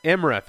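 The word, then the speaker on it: Imra: One of the other things, one of the other things I wanted to talk about was we find out more about Irma's, no, how do you say her name? Saturn Girl? – Emra, thank you.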